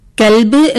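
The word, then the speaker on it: കൽബ് (كلب): അതുപോലെ ക എന്ന അക്ഷരത്തിന് 2 ഉച്ചാരണങ്ങൾ വരുന്നു. ഒന്ന് ക, ഒന്ന് ക. കൽബ് എന്ന് പറയുമ്പോൾ നായ എന്നർത്ഥം വരുന്നു. എന്നാൽ കൊൽബ് എന്ന് വരുമ്പോൾ അതിന് ഹൃദയം എന്നാണ് അർത്ഥം വരുന്നത്. അപ്പോൾ കായും കായും ശ്രദ്ധിച്ചിട്ടില്ലെങ്കിൽ അർത്ഥവ്യത്യാസം നമുക്ക് കാണാൻ സാധിക്കും.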